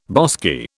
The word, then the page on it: bosky: British English